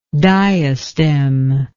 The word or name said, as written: diastem